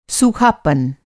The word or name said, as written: सूखापन